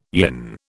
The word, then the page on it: yin: British English